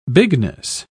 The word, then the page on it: bigness: British English